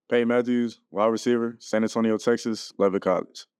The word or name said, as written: Payton Matthews